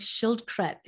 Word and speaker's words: Schildkret: I just think Schildkret is so beautiful.